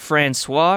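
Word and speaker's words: François: President Francois, am I saying that right, Jordan? – Yes.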